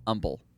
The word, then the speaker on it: humble: Do you say humble? – Humble? – Yeah. – Umble. – Umble. Unhum. It's a silent H.